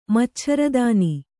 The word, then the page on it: ಮಚ್ಛರದಾನಿ: maccharadāni